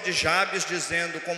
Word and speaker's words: Jabes: homem chamado Jabes, ou como alguns pronunciam, Jabez.